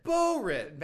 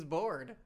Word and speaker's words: Barrett: Barrett. – Bad News. He makes it sound like he's Bad News Bored.